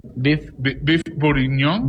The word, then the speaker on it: beef bourguignon: ¿Cómo se pronuncia? – Beef bourguignon.